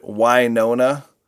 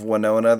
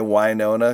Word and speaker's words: Winona: They pronounce it Winona instead of Winona. They say Winona because it's in Mississippi.